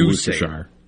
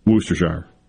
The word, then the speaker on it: Worcestershire: How do you say it? – I say Worcestershire. Worcestershire.